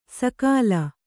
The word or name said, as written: ಸಕಾಲ